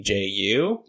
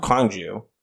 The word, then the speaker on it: Gwangju: So it's spelled Gwangju. – Yep. – Which is a K sound, not a G sound. – Oh. – Gwangju.